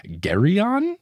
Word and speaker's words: Geryon: So Eritian nudges Percy to say hello to Mr. Geryon, is my guess of pronunciation, G-E-R-Y-O-N.